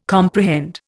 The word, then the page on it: comprehend: Indian